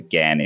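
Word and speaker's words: Ghan: I go up and down, and everyone in Australia pronounces it the GAN, but it's technically a shortened version of the Afghan Express was the original name, so GAN is how you're supposed to do it.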